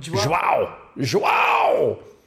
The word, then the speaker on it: João: It's Joe with a Whoa. – Nope. – Joao. Joao. All right.